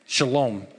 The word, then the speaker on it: Shalom: Salem is the Hebrew word for peace. Shalom is how you might pronounce it.